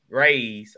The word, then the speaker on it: Raze: So uh we are brought to you by Raz Raze. I always say that wrong.